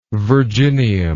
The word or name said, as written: virginium